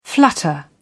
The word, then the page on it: flutter: British English